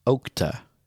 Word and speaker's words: okta: I'm going to pronounce it Okta. Okta? – There you go. Okta. – Now, how do you know that? Sounds like an Indian term. – Well, Okta? – Yeah, Okta. There's Oktas, yeah. O-K-T-A. It's odd. So if you can look that, you can gurgle that too. – The only reason I pronounce it Okta is for okra.